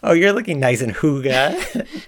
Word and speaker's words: Hygge: It's often mispronounced Higgy. It's actually pronounced more like Hygge.